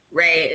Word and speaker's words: Rei: So Salty is spelled S-O-L-T-Y. Ray is R-E-I, like Ray Ayanami from Neon Genesis.